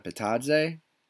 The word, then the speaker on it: Bitadze: How do you pronounce that? Bitadze? – Yeah, I think that's right.